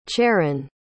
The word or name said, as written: charron